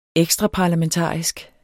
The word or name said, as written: ekstraparlamentarisk